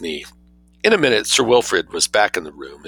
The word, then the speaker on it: Wilfrid: W-I-L-F-R-I-D, I'm not mispronouncing Wilfried.